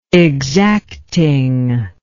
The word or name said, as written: exactingness